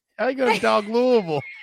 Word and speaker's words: Louisville: Oh, the only correct way to pronounce it, which is Louisville.